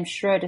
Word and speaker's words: Schreuder: You'll find me, Sue, S-U-E, and my surname Schroeder, spelled S-C-H-R-E-U-D-E-R.